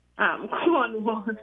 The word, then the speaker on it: Cornwall: Cornwall, C-O-R-N-W-A-L-L.